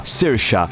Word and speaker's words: Saoirse: scritto così, Saoirse, in realtà si pronuncia Saoirse ed è la parola irlandese, cioè gaelica per libertà, Saoirse.